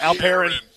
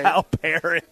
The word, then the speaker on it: Alperen: Oh, Windy doesn't know how to say Alperen. Like, Alperen. – You gotta, if you're going to roast him, you got to say his name correctly.